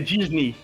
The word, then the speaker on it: Disney: A pronúncia é Disney.